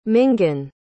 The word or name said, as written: mingan